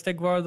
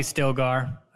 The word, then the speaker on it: Stilgar: Thus ends our first encounter with Stigvar, the leader of the CH. – Right. And okay. Is it Stigvar or Stilgar? – That's probably Stilgar.